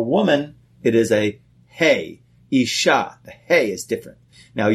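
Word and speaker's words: isha (אשה): And the word for woman is isha, which is aleph, shin, he.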